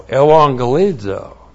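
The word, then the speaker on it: euangelizo: E-U-A-N-G-E-L-I-Z, long O. Evangelizo.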